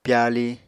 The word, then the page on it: pyali: Apatani